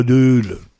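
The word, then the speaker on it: Deûle: Et puis, à Lille, c'est le canal de la Deule. Ah oui, on prononce comme ça.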